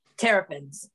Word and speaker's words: Terrapins: How do you say it? – Terrapins.